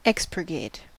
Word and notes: expurgate: American English

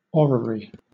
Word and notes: orrery: British English

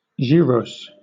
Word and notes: gyros: British English

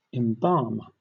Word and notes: embalm: British English

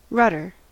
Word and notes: rudder: American English

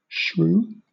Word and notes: shrew: British English

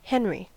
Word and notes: henry: American English

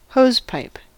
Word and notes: hosepipe: American English

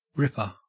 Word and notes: ripper: Australian English

